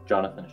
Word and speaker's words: Jonathan: Jonathan Schaefer, J-O-N-A-T-H-A-N, S-C-H-A-E-F-F-E-R.